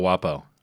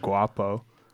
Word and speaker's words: Guapo: El Guapo means the handsome one, and it's pronounced Guapo, unless you've heard it said that way, like Washington Post.